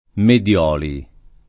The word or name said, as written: Medioli